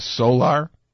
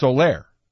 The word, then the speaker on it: Soler: this new phenom of theirs called Solar, S-O-L-E-R, but it's pronounced Solaire. Okay, but in print, it looks like solar, S-O-L-E-R.